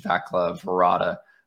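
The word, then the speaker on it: Václav Varada: He mentioned that his coach, Vaclav Varada, which I apologize. – Former Shark. – Apologize for the pronunciation if it's wrong. – No, that's right.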